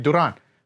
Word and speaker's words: Duran: That's why I don't say my name like Duran, even though that's how you say it, Duran. Because then I'm like, hey, my name's Ev Durant.